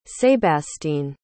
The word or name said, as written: sébastien